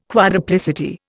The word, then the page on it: quadruplicity: Indian